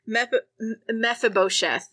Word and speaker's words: Mephibosheth: Mephibosheth is what we're going with.